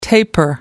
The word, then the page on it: taper: American English